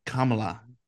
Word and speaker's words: Kamala: Our guest today is Amy Kamala.